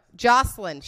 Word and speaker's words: Joycelyn: Miss Joycelyn. Did I say her name right? Nope. Jocelyn.